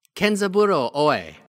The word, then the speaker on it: Kenzaburo Oe: This week's worst Asian ever is Kenzaburo Oe.